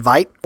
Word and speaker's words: Veidt: Adrian Veidt, or Vet, depending on your pronunciation. – Let's hope they go with Vet.